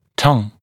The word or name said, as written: tongue